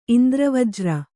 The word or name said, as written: ಇಂದ್ರವಜ್ರ